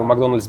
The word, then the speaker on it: Макдоналдс: И поэтому говорю Макдональдс без мягкого знака, потому что это так правильно считается.